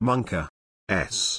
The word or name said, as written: monkaS